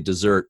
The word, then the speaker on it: Desert: I know the traditional pronunciation is desert, kind of based on the Mount Desert from French. – French, Ile de Mont Desert. – Yeah. So I tend to say dessert based on the traditional pronunciation, but we'll go with MDR for the rest of the interview.